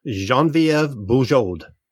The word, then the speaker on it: Geneviève Bujold: Geneviève Bujold. – Good job with the French pronunciation and everything.